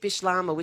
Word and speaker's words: Bislama: Is that Bish is that V or B? – B. Bish. So it's spelled Bislama. But from when I went there, my memory is it's Bishlama. So a Creole language.